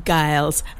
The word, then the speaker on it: Giles: Also, Jerry says, J. Giles rhymes with piles.